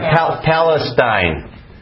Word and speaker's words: Palestine: Palatine. I'm terrible. It's amazing you guys throw up. What was I trying? Palestine. Is that right? Okay, I got it.